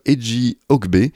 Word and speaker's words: Eji Ogbe: Le titre de l'album représente quatre bâtons, le signe plus suivi de quatre autres bâtons, mais ça se prononce Eji Ogbe,